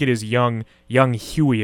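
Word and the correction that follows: Younghoe: is how it's pronounced. – So I just looked at it. It's Young Way.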